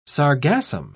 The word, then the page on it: sargassum: British English